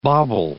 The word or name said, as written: bauble